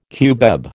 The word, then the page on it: cubeb: American English